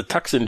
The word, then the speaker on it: Tucson: He just, T-U-C-S-O-N, Tucson. Tucson.